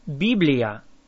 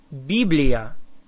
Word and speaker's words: Библия: Biblia. Biblia.